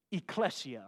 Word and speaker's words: ekklesia: The word that Paul used for church was ekklesia, or ekklesia. It's spelled E-K-K-L-E-S-I-A.